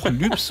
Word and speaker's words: Lübs: Aber auch Lübs und Klütz, also gesprochen mit langem Ü, die werden immer mal wieder zu Lübs und Klütz.